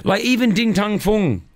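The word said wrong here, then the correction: Din Tai Fung: Ding Tai Fung.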